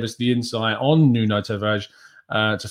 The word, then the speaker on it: Tavares: You don't actually pronounce the, the R in a hard way, it's just Tavares, um, it seems.